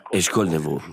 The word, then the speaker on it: Eshkol Nevo: La prima si chiama Eshkol Nevo, questa è la pronuncia originale, ma poi ho una seconda personalità che risponde invece alla pronuncia in italiano, che pure mi piace molto. Eshkol Nevo, così mi chiamano in Italia, e mi piace.